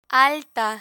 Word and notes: alta: spanish

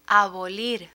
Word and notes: abolir: spanish

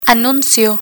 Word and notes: anuncio: spanish

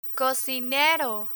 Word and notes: cocinero: spanish

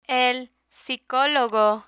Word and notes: el psicologo: Spanish

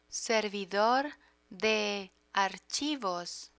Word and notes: servidor de archivos: spanish

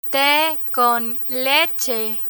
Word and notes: te con leche: spanish

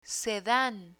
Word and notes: sedan: spanish

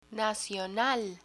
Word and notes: nacional: Spanish

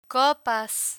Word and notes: copas: Spanish

